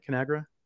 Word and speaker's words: Conagra: Conagra? – Yeah.